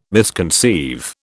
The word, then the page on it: misconceive: British English